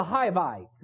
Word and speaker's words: Hivites: And it's the Hivites is how it's pronounced in the scripture.